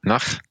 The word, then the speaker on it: nach: Like in Tlingit, it's called nach. – Nach? – Nach.